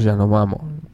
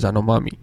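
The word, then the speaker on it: Yanomamö: Y aparece un antropólogo que estudia en Yanomamo, corrígeme si lo digo mal, ¿no? Yanomamo. – Estudia los Yanomamo. Ahora, yo los estudié como los Yanomami en el instituto.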